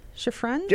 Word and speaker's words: Lalo Schifrin: And then, I'm going to butcher this name, Lalo Schifrin? Schifrin? – Yeah, sounds right.